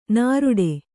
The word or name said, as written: ನರಡಿ